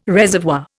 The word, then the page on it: reservoir: Indian